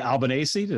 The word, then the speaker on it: Albanese: His name is Anthony Albanese.